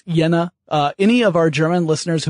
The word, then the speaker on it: Jena: I suppose it would be Jena, Germany. The J is generally pronounced like a Y.